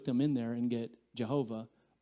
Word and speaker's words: Jehovah: Jehovah. We don't really know if that's the way it should be pronounced. Some say Yahweh.